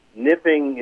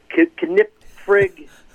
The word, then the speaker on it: Knipfing: It's spelled K-N-I-P-F-I-N-G. So they would say Knip Frig, you know, Kipling. Every bad way to say the name you can possibly think of, they said it.